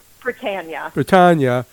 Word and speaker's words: Prytania: The house on, is it Prytania? Is that how you say it? – Prytania.